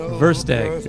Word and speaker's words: Versteeg: John Versteeg, pronounced like the first egg. – Versteeg.